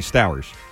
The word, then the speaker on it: Stowers: Okay, Stowers. I prefer Stowers because it rhymes with Knowers.